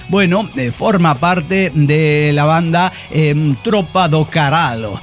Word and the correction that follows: Carallo: Tropa do Carallo, sí. Lo estoy pronunciando, es galego, disculpe.